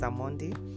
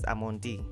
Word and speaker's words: Amondi: This is Love Always and my name is Amondi. A few of you are like, why do I say Amondi? My name is Amondi. It doesn't matter, man. But unajua jina yangu. It's spelled Amondi.